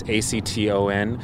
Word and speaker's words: Acton: It looks like it's spelled action, but it's without the I. It's Acton. They make a handful of boards that are very affordable and have an okay reputation.